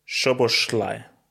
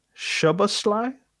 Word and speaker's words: Szoboszlai: Szoboszlai. – Szoboszlai. I can't pronounce the name. Homie from Hungary. Szoboszlai. Say again, slow. – Szoboszlai. – Szoboszlai? – Yeah.